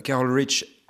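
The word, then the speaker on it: Carole Rich: Carol Rich, tu vois, je l'ai prononcé.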